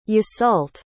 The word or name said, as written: Yseult